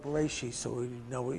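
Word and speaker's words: Bereshit (בראשית): Thus, in the beginning, Hebrew, and you can see the Hebrew there, that's Hebrew that's pronounced Bereshit. English pronunciation is Bereshith or Bereshit. Some people pronounce it Bereshith. It depends on where in the world you come from as a Jewish person, which dialect you speak, is the first word of the book of Genesis, and according to Jewish custom, the name of the book.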